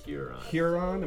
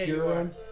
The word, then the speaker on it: Huron: Huron. – Am I saying it right? – Yeah, Huron.